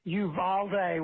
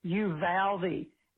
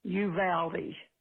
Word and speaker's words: Uvalde: I just wanted to let you know that here in Texas, we don't say Uvalde. We say Uvalde. It reminds me of you pal me, you know, it rhymes.